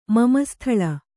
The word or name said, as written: ಮಮಸ್ಥಳ